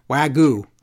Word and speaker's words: Wagyu: Wagyu beef, how do you pronounce it? Wagyu. Like Ragu.